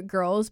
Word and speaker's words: Grlz: at Growler Girls, but girls is spelled like G-R-L-Z,